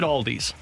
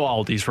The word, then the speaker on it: Aldi: But apparently Aldi is incorrect. It should be considered and called Aldi.